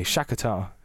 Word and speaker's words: Shakhtar: You managed to mispronounce both of those teams. That was impressive. – Shakhtar. Oh, sorry. Shakhtar Donetsk. – There's an extra syllable coming in there.